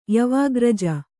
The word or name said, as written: ಯವಾಗ್ರಜ